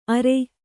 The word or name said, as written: ಆರೆ